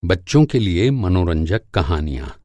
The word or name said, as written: मनोरंजक